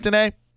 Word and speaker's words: Danai: Did I get that right, Danai? – Danai. – Of course, Danai.